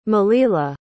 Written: malila

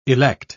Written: elect

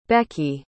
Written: becky

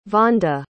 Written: vonda

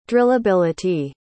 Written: drillability

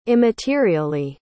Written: immaterially

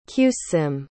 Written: qssim

